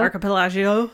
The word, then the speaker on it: archipelago: You know, I can't say that word. Archipelago. – Archipelago. – Archipelago. – Perfect. – Did I say it right? – Yeah.